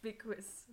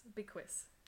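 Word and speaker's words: Bilquis: No, it's Biquis. Biquis.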